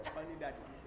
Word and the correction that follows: fuddy-duddy: No, not funny. Fuddy.